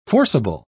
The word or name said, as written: forcible